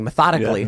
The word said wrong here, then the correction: methodically: Yeah, it was methodically. The emphasis on the wrong syllables.